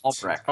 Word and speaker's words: Albrecht: Is it Albrecht? Do I say the T or Albrecht. Is it Albrecht? – Albrecht.